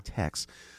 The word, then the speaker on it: Taix: We favored, and of course, in Echo Park, there's this old French restaurant spelled T-A-I-X, pronounced Tex.